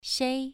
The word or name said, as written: shei2